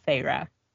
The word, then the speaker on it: Feyre: But, like, towards the beginning, the sister talks to her, and she's like, oh, Feyre, and it's, like, how to pronounce, because, like, and then I was like, that's a clever way to throw in how to pronounce her name, so Feyre.